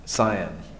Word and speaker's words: scion: So how you pronounce that word? Scion? Scion.